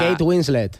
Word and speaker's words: Kate Winslet: Kate Winslet.